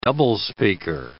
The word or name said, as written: doublespeaker